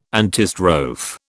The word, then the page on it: antistrophe: British English